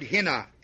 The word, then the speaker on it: hina: The first one is the word hina, H-I-N-A. The second word is the word pleroteo, P-L-E.